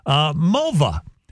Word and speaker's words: Mulva: Mulva. Rhymes with vulva.